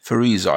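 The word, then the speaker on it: Fairuza: One is it Faiza Balk or Fariza Balk? – Fariza, I've always said it to be.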